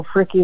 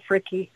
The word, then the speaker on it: Fricke: I'm on there as Cheryl Fricky. That's my, that was my married name before I remarried. – Spell that for us. How do they spell that? – F-R-I-C-K-E, Cheryl Fricky.